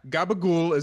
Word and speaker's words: Capicola: Gabagool is how Southern Italians pronounce Capicola in the East Coast.